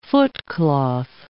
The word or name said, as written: footcloth